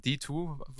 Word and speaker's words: D2: Ähm, D2 wird das einfach ausgesprochen.